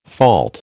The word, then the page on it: fault: American English